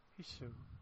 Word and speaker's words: Hissho: Is that pronounced correctly? – H-I-S-S-H-O. Hisho. Hisho? – Okay.